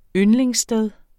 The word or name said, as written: yndlingssted